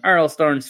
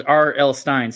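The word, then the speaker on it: R.L. Stine: It's part of a film event trilogy on Netflix based on R.L. Stein's Fear Street. That was R.L. Stein. Sorry. Mispronounced his name.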